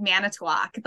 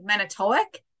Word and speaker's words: Manitowoc: manitowoc like people are always like manitowoc and I'm like, no, it's Manitowoc.